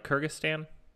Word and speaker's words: Kyrgyzstan: Did I say that right? Kyrgyzstan? – Yes.